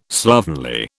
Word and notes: slovenly: British English

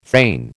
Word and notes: thane: American English

